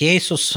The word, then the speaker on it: Asus: Правильно говорить Asus, э, ну, так э, в английском произношении должно говорить. Но ты знаешь, э, давай уже всем расскажем, что тайваньцы сами на протяжении там больше чем 20 лет говорили Asus, и никого это никогда не смущало, но потом все все-таки решили решили, что давайте будем говорить правильно и давайте будем стремиться говорить Asus.